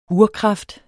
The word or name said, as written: urkraft